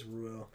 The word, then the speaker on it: Ruel: R-U-E-L. – Oh, yeah, it is Ruel. – Yeah. – Isn't it Ruel, like dual? – I was thinking Ruel.